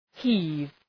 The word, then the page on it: heave: hi:v